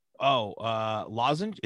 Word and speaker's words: lozenge: They also have, I still can't pronounce this word, Lazenge. – Lozenge? Is it lozenge? No. – Wait. It's probably lozenge, but lozenge with 4 milligrams of nicotine that comes in 3 flavors as well.